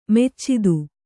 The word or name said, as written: ಮೆಚ್ಚಿದು